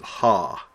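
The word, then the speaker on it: ha (ὁ): The nouns in the right-hand column are followed by the form omicron with a rough breathing. This is actually the masculine form of the word the, and is used to indicate the gender of the noun. Pronounce it ha.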